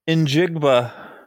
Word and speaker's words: Njigba: If how do you say his last name again? – Njigba. – Okay. That's not what it looks like, but okay. Njigba, if he's available at 24.